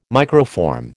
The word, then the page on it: microform: American English